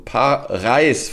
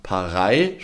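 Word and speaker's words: Parey: Eine Hörerin hat uns gebeten, das Vogelbuch, aus dem wir immer vorlesen, Paris Vogelbuch, wie ich von ihr gelernt habe, nicht Paris, schreibt man nämlich mit EY. Sie selber hat auch so einen ähnlichen Namen, Paris, spricht man es aus, mal vorzustellen.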